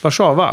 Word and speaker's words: Warszawa: Warszawa mm. uttalas det. – Ja. Inte engelska utan, nej, nej.